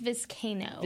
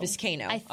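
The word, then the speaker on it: Vizcaino: Before I get back to that, I did want to mention, you talked about the kicking situation with Tristan Viscano. – Yep. – Viscano? Have we decided how to say his last name? – I think it's Viscano. – Viscano.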